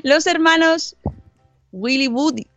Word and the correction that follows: Willoughby: Will Woody, Will Willow, Willoughby. – Willoughby. – Willoughby. Los hermanos Willoughby.